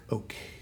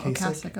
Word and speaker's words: Ocasek: But apparently Rick Ocasek, I think it's actually pronounced. – Oh, Ocasek, okay. I've always said Ocasek, but for some reason I feel like I saw an interview and he said it's Ocasek or something.